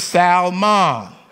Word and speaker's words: Salmon: No, that's salmon. The L is silent. This is Salmon.